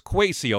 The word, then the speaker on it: Kwesi: Is it Kwesi or Kwesi for the new GM of the Minnesota Vikings? Because we know pronunciation. It's Kwesi, okay, because pronunciation on the radio is important.